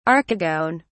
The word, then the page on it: archegone: British English